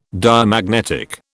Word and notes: Diamagnetic: British English

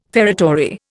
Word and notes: feretory: Indian

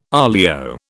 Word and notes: olio: British English

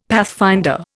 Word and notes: pathfinder: Indian

